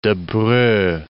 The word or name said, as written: Debreu